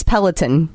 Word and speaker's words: Peloton: So yes, it's Peloton. – Peloton. – Or you can say Peloton. Just don't say Peloton.